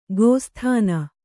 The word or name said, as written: ಗೋಸ್ತನಿ